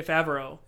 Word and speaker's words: Favreau: Favreau. Yeah, Favreau.